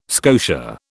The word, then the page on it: scotia: British English